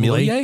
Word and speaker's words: Milei: Argentina President Javier, I hate to mispronounce his name, Milei? Javier Milei? – Yeah, sure.